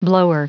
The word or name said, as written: blower